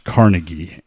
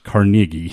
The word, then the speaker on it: Carnegie: The name Carnegie is not pronounced Carnegie. It's pronounced Carnegie.